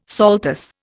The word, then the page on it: saltus: Indian